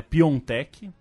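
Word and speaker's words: Piątek: É, alguém vai dizer que a pronúncia certa é Piontek, mas não vou, não vou ser eu.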